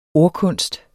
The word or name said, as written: ordkunst